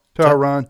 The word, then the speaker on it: Tehran: Is that how you pronounce it? – That's right. – Tehran.